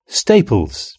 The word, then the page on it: staples: British English